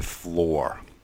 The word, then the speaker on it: floor: The double O in floor is not an oo sound, it's an O sound, floor. And the OU sound in flower is ow, ow, flower, flower, flower.